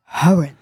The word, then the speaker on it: Horan: Hier gleich für euch Musik aus den Charts von Mai Smith und Niall Horan. Oh, manchmal spreche ich den Namen merkwürdig aus. – Niall Horan. – Horan. – Guten Morgen. – Also kein stummes H.